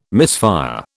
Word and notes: misfire: British English

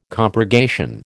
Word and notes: compurgation: American English

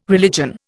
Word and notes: religion: Indian

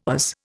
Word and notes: Oz: Indian

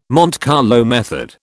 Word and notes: Monte Carlo method: British English

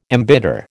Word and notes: embitter: American English

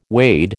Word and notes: wade: American English

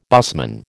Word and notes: busman: American English

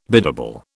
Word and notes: biddable: British English

